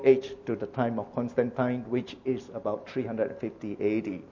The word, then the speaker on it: Constantine: Or some people pronounce as Constantine. In this part of the world, it pronounces as Constantine.